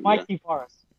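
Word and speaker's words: Mikey Varas: Yeah, did I say his name wrong? – Mikey Varus. – Yeah.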